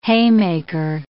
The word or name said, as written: haymaker